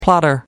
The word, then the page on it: plotter: American English